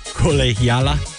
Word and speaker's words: La Colegiala: La Colegia. I got to pronounce this correctly. La Col- Colegiala.